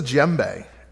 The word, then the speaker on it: djembe: This is a djembe, and it's not spelled how you think either. It starts with a D. I don't know why. The D is silent. Djembe.